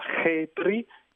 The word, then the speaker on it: Khepri: Peraltro lo scarabeo in egiziano si legge come la parola Khepri,